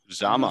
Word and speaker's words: Uzomah: Who is CJ Uzomah? Am I saying this correctly? – Zama. – I was about to say, I don't think there's a Mwah.